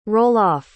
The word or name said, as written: roll-off